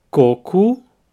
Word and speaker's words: coco: So if you mispronounce coco and say cocô instead, you will be asking for poo water.